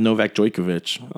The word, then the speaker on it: Novak Djokovic: But in the men's, finished up today, and we have Novak Djokovic.